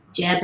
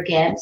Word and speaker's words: Geb: Jeb or Geb, it's pronounced, and holding up Nut, but with him, but you don't see her because there's the seen and unseen forces of nature.